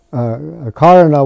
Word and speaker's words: karana: Karana with a long A is cause, but the karana with a short A just means event.